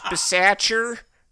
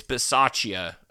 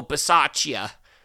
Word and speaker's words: Bisaccia: Coach Bisaccher, it's Bisaccia. Bisaccia. Anyways, yeah.